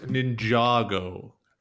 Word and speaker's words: Ninjago: I've heard from a lot of people that it's good, and it's pronounced Ninjago. – Okay. – It's Ninja and Lego, Ninjago.